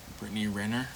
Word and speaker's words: Brittany Renner: Brittany Renner.